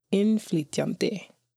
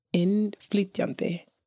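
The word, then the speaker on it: innflytjandi: And in regards to the Icelandic word of the episode, it is immigrant, which is pronounced inflytjandi, inflytjandi. So I'll just say a little bit slower, inflytjandi, which in, of course, which it's I-N-N, but of course it means coming in.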